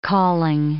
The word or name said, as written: calling